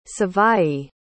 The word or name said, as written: savaii